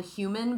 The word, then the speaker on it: HVMN: Now the company is called Human, but it's spelled like a dumb Silicon Valley way, which is H-V-M-N.